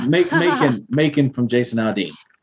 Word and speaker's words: Macon: So my number three was Macon. I think that's how you say it. Macon by Jason Aldean. – Macon, Georgia. Macon.